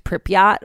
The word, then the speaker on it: Pripyat: So basically this Instagram model faked a photo shoot of herself at Chernobyl and like geotagged Pripyat or whatever.